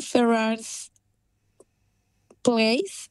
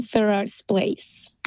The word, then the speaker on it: Ferrars Place: Es un Ferrars Place, South Melbourne. No sé si mi pronunciación. One Ferrars Place. – One Ferrars. – Ferrars, ¿de? – F-E-R-R-A-R-S, Place, South Melbourne, de 2 okay.